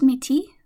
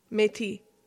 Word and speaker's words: Métis: One note, it's pronounced Métis. – Métis.